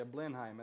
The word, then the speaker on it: Blenheim: Yeah, Blenheim, that's how you say it.